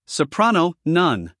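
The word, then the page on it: soprano: uk səˈprɑːnəʊ; en-us səˈprænoʊ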